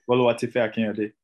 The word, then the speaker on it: Boluwatife Akinyode: Boluwatife Akinyode, right? Did I say that right? – Boluwatife Akinyode. – Yeah, there we go.